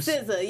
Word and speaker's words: SZA: SZA.